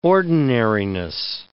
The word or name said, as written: ordinariness